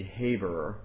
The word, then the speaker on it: Eber: Interestingly, John Owen thinks that the Hebrews got their language from Eber, because if you pronounce this in a Hebraic way, Eber would be Haber, from whom the Hebrews probably got their language.